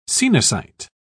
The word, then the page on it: coenocyte: British English